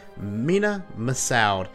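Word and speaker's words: Mena Massoud: Mina Massoud.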